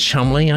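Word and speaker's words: Cholmondeley: Burfautonley, Burfautonley, probably pronounced Chumley, I should think, um, who lives in a crumbling stately home in Gloucestershire.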